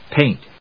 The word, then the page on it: paint: péɪnt